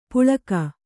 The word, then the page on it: ಪುಳಕ: puḷaka